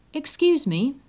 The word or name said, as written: excuse me